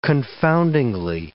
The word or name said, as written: confoundingly